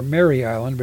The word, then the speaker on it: Mare Island: on Mare Island, or Mary Island, but it's M-A-R-E, whatever the pronunciation, near Highway 37.